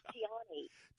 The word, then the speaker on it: Tiani: Did I say your name okay, or did I butcher it? – Well, actually, you made it sound pretty good. It's Tiani.